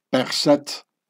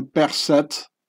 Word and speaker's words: Berset: Well, I prefer the Demotic Pharaonic pronunciation, if you don't mind. Berset. The French pronunciation would be Berset. The Swiss Germans, who are most of the Swiss, are Swiss Germans. They also say the Demotic Pharaonic pronunciation, Berset.